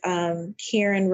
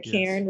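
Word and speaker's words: Karan: Twitter is Karen Rhodes. And remember, my name is spelled with two A's. So that makes me a little different. It's K-A-R-A-N. So Karen Rhodes.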